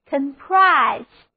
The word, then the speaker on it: comprise: 重音放在第二个音节上，O 字母发作 a， I 字母发 I 的本字母音，单词就读作 comprise。